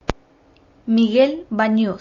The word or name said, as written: Miguel Banuz